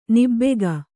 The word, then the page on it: ನಾಭಿಕ: nābhika